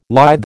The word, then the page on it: lithe: American English